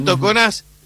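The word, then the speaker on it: Toconas: que tuvimos un entrevero porque tiene acento en la pronunciación pero no en la escritura, el apellido Toconás,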